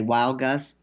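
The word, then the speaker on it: Weilgus: It's W-E-I-L-G-U-S, Wild Gus. I would say Wild Gus.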